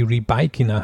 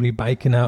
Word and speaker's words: Rybakina: Und Rybakina Übrigens, die BBC-Kommentatoren haben auch darauf hingewiesen, jetzt wo ich selber mal ausspreche, sie hat denen wohl in einem Meeting gesagt, dass sie Rebaikina heißt, also so wie es Sue Barker nachher auch bei der Siegerehrung ausgesprochen hat, aber die Kommentatoren waren sich selber nicht ganz sicher, haben immer Rebaikina und Rybakina gesagt.